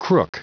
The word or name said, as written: crook